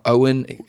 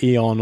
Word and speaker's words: Eoin: He's got Owen. It's spelled E-O-I-N. – We would have said Eon or something.